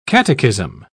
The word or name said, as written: catechism